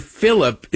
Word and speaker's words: Phillip: And you cite April Ryan and Abby Phillips. It's Abby Phillip, by the way.